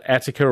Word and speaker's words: Ateca: So look, the Cupra Attica or Ateca, depending on who you chat to about the pronunciation, is as you probably know, and you mentioned Volkswagen.